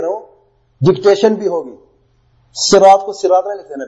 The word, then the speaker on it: صراط: پہلا لفظ ہے رجل، آدمی، رحیم، رحم کرنے والا، یوم، دن، صراط، تلفظ بھی سنیں، سین نہیں، صراط، صاد سے.